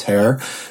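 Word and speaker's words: Tár: I believe it is pronounced Tear,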